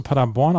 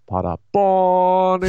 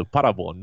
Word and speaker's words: Paderborn: Warum betonst du Paderborn auf der ersten Silbe? – Weil es nicht Paderborn in the USA ist. Das ist ganz schön schwierig hier am frühen Morgen für mich. Also Paderborn 1-2.